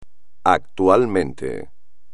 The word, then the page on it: actualmente: espagnol